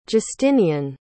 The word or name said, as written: justinian